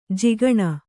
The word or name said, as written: ಜಕಣಿ